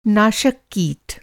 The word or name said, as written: नाशक